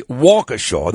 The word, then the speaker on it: Waukesha: But I think the town is actually Waukesha. That's how it's pronounced.